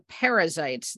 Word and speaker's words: Perizzites: the Perizzites, the Hivites, Parasites? – And the Jebusites. Parasites? – Parasites? I'm kidding. That was a joke, everyone. – How would you pronounce P-E-R-I-Z-Z-I-T? – You did it right.